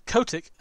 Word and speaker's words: Kotick: If we're bringing up EA's CEO, Andrew Wilson, and Activision former, you know, Bobby Kotick. Sorry, that's a hard name to say. Then I think we'll get there. – Yeah, Kotick. I think his name is Kotick.